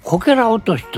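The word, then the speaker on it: 杮落とし: で、あのこけら落としといいまして、劇場できました。最初に上演するお芝居あるいはミュージカルをこけら落としと呼んでおります。これね、なんでか知らん漢字で書きますとね、柿という果物ありますね、はい。 – で柿落としと書いてこけら落としと読むんですね。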